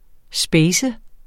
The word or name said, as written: spacet